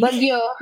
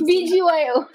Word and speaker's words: BGYO: And how do you say that? I'm sorry. – Bagyo. – But it's BGYO. – BGYO. I'm sorry because I'm Pinoy. I'll say Bagyo. – I also say Bagyo.